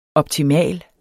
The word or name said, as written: optimal